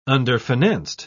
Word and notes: underfinanced: British English

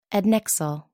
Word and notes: adnexal: American English

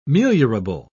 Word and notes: meliorable: British English

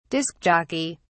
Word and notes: disk jockey: British English

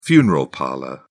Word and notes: funeral parlour: British English

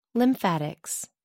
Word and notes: lymphatics: American English

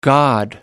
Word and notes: god: American English

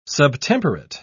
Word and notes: subtemperate: British English

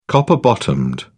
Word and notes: copper bottomed: British English

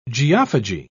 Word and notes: geophagy: British English

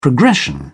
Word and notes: progression: British English

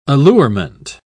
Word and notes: allurement: British English

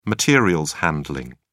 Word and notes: materials handling: British English

